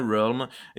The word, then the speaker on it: Realm: Chosen Realm ou Realm On va demander au seul anglophone de nous deux comment on prononce. Bah, comme il l'a dit. Realm. Et il nous confirme, c'est bien Realm.